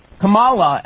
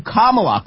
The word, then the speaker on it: Kamala: No, the wrong syllable. Oh, my God, she calls her Kamala as opposed to Kamala.